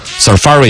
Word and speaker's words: Safari: 他还专门搜了一个这个英语字典那个出来，实际的发音呢是 Safari 应该是这样子读，嘎。 Safari 应该是重音在第二个，好像我之前是有印象的 Safari 浏览器，苹果的浏览器 Safari。